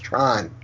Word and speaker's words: Tran: T-R-A-N, but pronounced Tron.